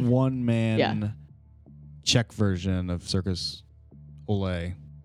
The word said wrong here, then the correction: Cirque du Soleil: Circus Olay? Circus Soleil? – Cirque du Soleil.